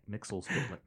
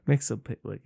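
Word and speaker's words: Mxyzptlk: What about Metzeplick? Is that how you say his name? – Mr. Mixelsplick. – Mixelsplick.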